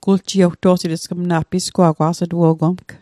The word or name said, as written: guljiewto'sit